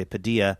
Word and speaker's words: Padilla: And his name is spelled, P-A-D-I-L-L-A, and we were calling him Jose Padilla, which would be the traditional, I think, pronunciation. And his family reached out to NPR and said, "We say Padilla."